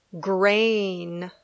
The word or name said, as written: grain